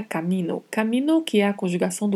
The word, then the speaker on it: camino: A única particularidade de pronúncia aqui é a letra A, que é mais aberta. Pronunciamos camino. Por, que é uma preposição, atenção ao vibrato, por.